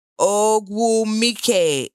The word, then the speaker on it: Ogwumike: Ogwumike. If you can say Oluwa, you can say Ogwumike.